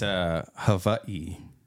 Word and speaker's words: Hawaii: In the Hawaiian language, W is pronounced with a V. – Hawaii. Like that? – Hawaii.